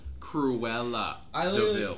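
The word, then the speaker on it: Cruella De Vil: Cruella DeVille.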